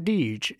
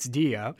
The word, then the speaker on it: Dige: It's spelled D-I-G-E, so it's not Deej, it's Dia.